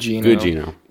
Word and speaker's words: Gugino: But, you know, Netflix is promoting this movie and you have, you know, Carla Gugino, did I pronounce it right? – Gugino. – Carla Gugino wearing, you know, a sheer silk dress handcuffed to a bed.